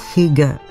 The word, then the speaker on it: Hygge: Se pronuncia más o menos Hygge. Creo que lo hago bastante bien.